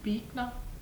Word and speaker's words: Büchner: Büchner.